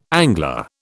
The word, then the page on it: angler: British English